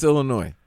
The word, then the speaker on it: Illinois: I was just about to say, when you said Illinois, it's Illinois.